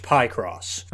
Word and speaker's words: Picross: And second of all, I can read, I understand grammar, and that is pronounced Picross. – I guess it makes sense, though, because it's a combo of pick and cross.